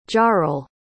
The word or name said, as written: jarrel